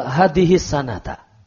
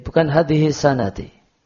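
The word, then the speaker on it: hadhihi sanata (هذه السنة): Kalau Ramadhana, maka hadhihi sanata. Hadhihi sanata. Bukan hadhihi sanati.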